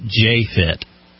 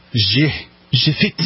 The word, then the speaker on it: JEFIT: But in my head, I pronounce it J-Fit. It's spelled J-E-F-I-T. – Okay. – And it's a workout app. – J-Fit. – I always told myself that I would pronounce it J-Fit.